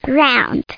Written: round

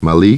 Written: Molly

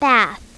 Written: bath

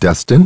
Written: Dustin

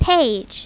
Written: page

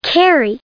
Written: carry

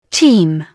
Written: team

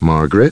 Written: Margaret

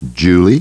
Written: Julie

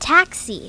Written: taxi

